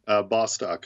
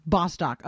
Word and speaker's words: Bostock: Let's teach me how to say it now. – Bostock, as far as I know. Yeah. – Bostock.